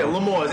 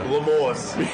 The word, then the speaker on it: L'Amour's: Yeah, yeah, L'Amour's. L-A-M-A-W-Z. – Yeah. – L'Amour's. – Yeah, put a French word in a Brooklyn neighborhood. – Yeah. – We're going to butcher that shit.